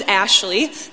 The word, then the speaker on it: Ashley: Now, if you've ever got it back, my name is Ashley, A-S-H-L-E-Y.